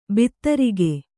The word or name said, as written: ಭದ್ರಕ